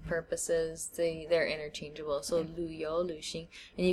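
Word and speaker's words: lǚxíng: So it's L, V, or U with the umlaut on top of it, and then X-I-N-G. Luxing.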